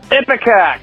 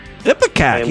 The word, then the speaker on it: Ipecac: You correctly pronounced and gave the correct definition for Ipecac. – Ipecac, yeah.